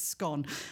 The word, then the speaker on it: scone: and he said he pronounces it the same way as me, and it's scone.